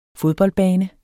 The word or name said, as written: FODBOLDBANER